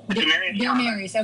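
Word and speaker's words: Demaryius: Our wide receivers, Dermius Thomas, how do you say that? – Dermarius, okay, from the Denver Broncos.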